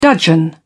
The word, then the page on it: dudgeon: British English